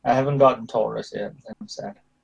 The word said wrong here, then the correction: Turles: You mean Turles.